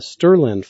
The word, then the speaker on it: strlen: The strlen function, or sometimes just pronounced string length in the last two lines there, it works the same way.